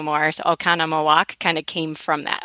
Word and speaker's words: Oconomowoc: But to pronounce the city is Oconomowoc.